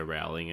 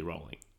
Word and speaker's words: Rowling: In her recent interview with The Weekend magazine, she made it clear that it rhymes with bowling. – Rolling. – We got it right. – We did? That was neither of our options. – Yeah. – Okay. All right. – She says her name is normally pronounced as in it rhymes with howling, so Rowling, and she just doesn't bother to correct anyone, but it's actually Rolling.